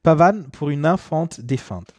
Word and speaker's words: Pavane pour une infante défunte: Wir hören von Maurice Ravel, Pavane pour une infante défunte. Habe ich das richtig gesagt? Kannst du das nochmal? – Pavane pour une infante défunte. – Défunte. Ja. Ja, guck mal, krass.